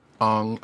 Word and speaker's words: Aang: Aang, Aang.